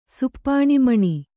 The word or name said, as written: ಸುಪ್ಪಾಣಿಮಣಿ